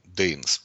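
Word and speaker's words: дейнос (δεινός): Дина, ну, дейнос, правда, ну, правильно по-гречески произносится, ужасный, корида, креветка, ужасные креветки.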